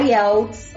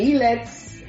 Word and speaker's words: IELTS: Tem aluno que chama de ILETS. – ILETS. mas o correto, a pronúncia correta é IELTS, né? Então o, o, o stress da, da palavra está no E e TOEFL.